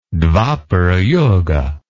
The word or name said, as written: dvaparayuga